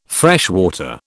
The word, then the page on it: freshwater: British English